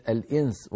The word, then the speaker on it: الإنس: الأمر الثاني أخي، لما تخفي النون عند السين في كلمة الإنس وما خلقت الجن والإنس، إنس تنتقل من مخرج الهمزة المكسورة إي إلى مخرج السين مباشرة، لكنك لا تنطق سينا بل يبقى من النون غنتها ويأتي من الفم صويت ضعيف هكذا.